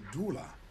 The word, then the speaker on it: doula: actress, and you're also a doula.